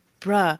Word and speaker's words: bruh: And she's like, it's not bro, it's bruh or whatever.